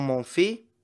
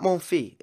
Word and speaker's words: Monfils: It's not Monfils. It's Monfils. You are supposed to pronounce the S. I can't believe this. This is unbelievable. I thought the French, if there's a consonant at the end, they get rid of it.